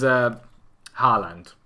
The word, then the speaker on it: Haaland: It is Haaland. – I think I couldn't get Haller out of my head, you see, yeah. So I was never going to get it. – I think it's pronounced Haaland anyway, but the wonder kid.